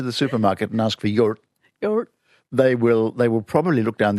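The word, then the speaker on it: yoğurt: And then you pronounce R and T, but instead of saying yogurt t, with a T at the end, you don't release the T, you hang on to it. So, yurt.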